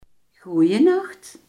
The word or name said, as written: goedenacht